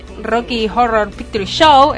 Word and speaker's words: Rocky Horror Picture Show: Bueno, Brian nos comenta, siguiendo la línea de Horror, que le encantaría que se estrene Rocky Horror Picture Show, esta vez lo dije bien.